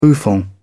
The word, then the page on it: bouffant: British English